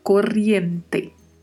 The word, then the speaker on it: corriente: E a pronúncia aí, que também temos que ter bastante cuidado. Este duplo R, que tem o som vibrato, né? E o T do final, que é T mesmo, não é né? Como falamos no português, corrente, né? Não, em espanhol é corriente, corriente. Então devemos pronunciar desta forma.